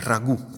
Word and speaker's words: ragù: Il ragù con l'accento sulla U può comunque condire anche il riso, eh?